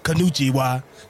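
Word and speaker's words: Konnichiwa (こんにちは): Big Baller Brand will bring konnichiwa.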